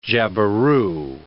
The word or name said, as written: jabiru